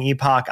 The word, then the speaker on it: Epoch: But something like the Epoch Times, and I know you're saying Epoch, I've heard it pronounced Epic.